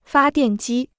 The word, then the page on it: 发电机: fā diàn jī